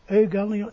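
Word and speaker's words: euangelion (εὐαγγέλιον): De GG als NG, zoals koningin. Hè? Dus angelon euangelion, eh, dus angelon.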